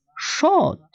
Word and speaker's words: shot: Причем, возможно, для того, чтобы реплика не казалась куцей, это одно слово произносится протяжно, дабы слышались хотя бы два слога «шот». Другие